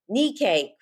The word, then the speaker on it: Nikkei: focusing on Nikkei cuisine. Saying that wrong. – Nikkei cuisine.